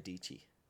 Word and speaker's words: Dici: And then I went through his grid, and he was an apprentice for Sarah Dici. Rhymes with peachy.